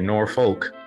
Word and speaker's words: Norfolk: I mean, Norfolk's one of those interesting cities where if you're not from Norfolk, you don't say Norfolk, you say Norfolk.